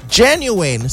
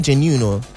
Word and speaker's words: genuine: Don't say genuino. It's not genuine. Is there any other way that we pronounce it? Some people say genuine. It's genuine. It's genuine. I think some people might say it's genuine. I don't know. But don't say genuine. It has to be said in three syllables.